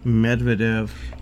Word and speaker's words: Medvedev: No, Medvedev.